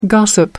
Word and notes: gossip: American English

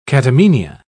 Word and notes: catamenia: British English

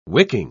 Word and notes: wicking: British English

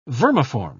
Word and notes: vermiform: British English

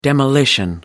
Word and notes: demolition: British English

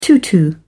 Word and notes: tutu: British English

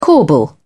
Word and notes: corbel: British English

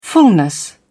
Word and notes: fullness: British English

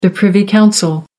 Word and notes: privy council: American English

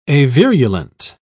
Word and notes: avirulent: British English

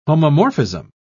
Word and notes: homomorphism: British English

